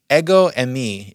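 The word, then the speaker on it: ego emi (ἐγώ εἰμι): So in the Greek, it's the term ego emi is the way that it's pronounced in Greek when he says, I am, ego emi.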